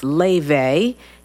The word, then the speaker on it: Laufey: It's her name is pronounced Laufe. It's spelled Laufey, and she's an Icelandic singer-songwriter, pianist, singing jazz.